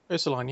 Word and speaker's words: Ursuline: how do you pronounce this one, Ursuline? – Ursuline, yeah.